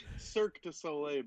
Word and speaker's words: Cirque du Soleil: Circus de Soleil. Cirque du Soleil. Bud. – Cirque du Soleil. Thank you.